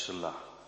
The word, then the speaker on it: Sela: Er staat Sela achter, hè? Of ik moet het eigenlijk uitspreken als Sela.